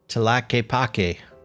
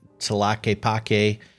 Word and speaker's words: Tlaquepaque: Tlaquepaque. Normally, I'm really very good with pronunciation. It's spelled T-L-A-Q-U-E-P-A-Q-U-E.